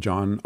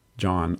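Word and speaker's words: Jon: My name, John Armstrong, and I spell J, as soon as I say that, I think I don't spell it.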